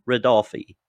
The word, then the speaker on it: Redolfi: Her name is Rachael Redolfi.